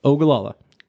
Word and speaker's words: Ogallala: Born and raised in Nebraska, 21 years, little town called Ogallala. – What? – Ogallala. – Got it. – So we can spell it O-G-A-L-L-A-L-A.